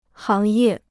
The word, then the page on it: 行业: háng yè